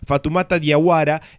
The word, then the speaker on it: Fatoumata Diawara: Fatoumata Diawara es la cantante.